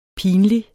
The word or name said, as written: pinlig